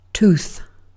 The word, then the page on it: tooth: British English